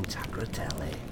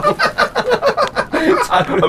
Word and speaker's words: Tagliatelle: Terrifying Tagliatelle. Down. – Said that wrong? Tagliatelle.